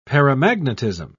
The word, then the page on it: paramagnetism: British English